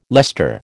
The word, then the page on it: Leicester: American English